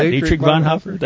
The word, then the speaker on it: Bonhoeffer: Yeah, Dietrich Bonhoeffer.